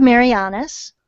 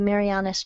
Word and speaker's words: Marianas: So, you've got the Marbled Marianas. – Okay. Is that how that's pronounced? – Yeah, like the Marianas Trench.